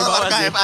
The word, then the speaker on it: archive: Ini bacanya arsip kan, arsip. – Arsive? – Aduh. – Bener enggak? Bener ya, teman-teman? – Salah. Yang bener apa dong? – Iya, bener sih. – Betul, archive ada sih tulisannya sih, bener sih. – Archive, yes. Oke.